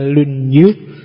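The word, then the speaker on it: Lunyu (论语): Yang pertama judulnya Dasu, yang kedua Lunyu, bacanya misah ya, kalau disambung jadi Lunyu. Ya. Kalau lunyu licin.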